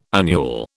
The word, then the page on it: annual: British English